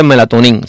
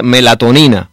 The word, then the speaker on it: melatonina: Y sobre el medicamento, no se dice melatonina, se dice melatonina, es la palabra correcta en español.